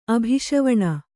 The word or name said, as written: ಅಭಿಷವಣ